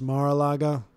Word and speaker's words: Mar-a-Lago: It's pronounced Mar-a-Lago.